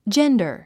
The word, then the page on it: gender: dʒéndə r; ジェンダァ